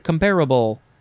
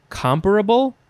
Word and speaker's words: comparable: I guess you could emphasize both the first and the second syllables, or either you could say comparable. Yes, some people say it depends on the context, comparable or comparable.